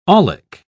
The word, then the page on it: aulic: British English